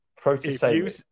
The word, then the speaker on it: Protasiewicz: Protasiewicz, that's how you pronounce it, apparently, right? – Okay.